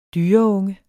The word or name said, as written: dyreunge